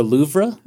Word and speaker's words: Louvre: Is that how you say it? The Louvre? – That's how I say it. – Okay. – Kind of like Brett Favre.